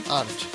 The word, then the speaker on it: Arch: Para los que están preguntando, el vocal quién es, es Arch. Así se escribe. ¿eh? – Arch. Así se pronuncia, perdón.